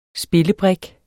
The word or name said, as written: spillebrik